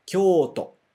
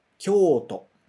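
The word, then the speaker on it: Kyoto (京都): Né, vamos ler aqui em cima de novo em hiragana direitinho com a pronúncia japonesa, ok? Kyoto. Mais uma vez. Kyoto.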